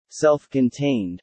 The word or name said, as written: self-contained